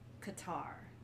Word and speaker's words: Qatar: It's pronounced like um, one of the characters in Avatar: The Last Airbender, Katara. Mm. – So it's Qatar.